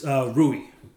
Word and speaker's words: Rui: Well, yes, it's Rui. And Rui.